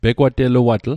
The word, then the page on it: pegwateluatl: Mi'gmaq